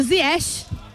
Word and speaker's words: Ziyech: A gente tem a pronúncia Ziyech, marroquina. – Na verdade. – Ziyech, como o nosso amigo Brandão me falou ontem, que eu demorei Ziyech. Um século para conseguir. – É Ziyech, então. – Ziyech. É, a pronúncia marroquina.